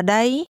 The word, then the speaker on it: đây: The second part of here has a mid-level tone. Now say again, there. – Ở đây. Đây. Ở đây.